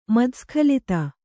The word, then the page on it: ಮತ್ತಸ್ಖಲಿತ: matta skhalita